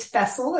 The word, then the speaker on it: Fessele: It is Fessel. It rhymes with vessel, like a boat.